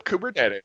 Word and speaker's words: Kubernetes: Like everything's coming up Kubernetes, or Everything's coming up Kubernetes.